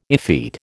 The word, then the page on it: effete: American English